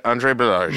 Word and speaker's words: Andre Balazs: I did not know that's how you pronounced his name. – You know, Andre Balazs.